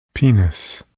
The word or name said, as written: penis